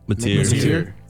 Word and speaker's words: Mateer: John Mateer? – Mateer.